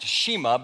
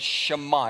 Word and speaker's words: Shema: You look at it on the screen there, it looks like it would be pronounced Shema, but it's Shema.